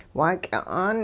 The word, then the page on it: waaky'a'aan: hupa